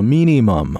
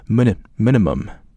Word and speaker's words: minimum: 这个词读的时候注意一下，因为它全都是短音，所以你读的时候一定得拉短，你不能拉长，不能读 minimum 啊，那就不好听啊，应该是 minimum 啊。